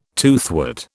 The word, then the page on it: toothwort: British English